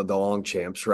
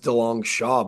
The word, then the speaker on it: DeLongchamps: I mean, I'm not going to correct anybody that calls me the DeLongchamps, right? Like, it's the ultimate fantasy football name. It's a lot cooler. It's actually pronounced DeLong Shaw, but who am I going to DeLong Champions?